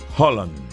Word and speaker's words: Haaland: En noruego esa doble A es una doble O. Es Haaland. Haaland. Haaland en la pronunciación correcta, pero nosotros los latinos y la gente en todo el mundo sigue diciendo Haaland.